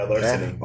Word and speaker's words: Larceny: Oh man, the guy who can't even pronounce shit is Larceny. Did I do it right? – Yeah, Larceny.